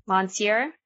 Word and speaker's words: Monsieur: Monsieur, Attorney General, I have the honor to inform you of an exceptionally serious occurrence.